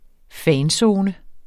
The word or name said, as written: fanzone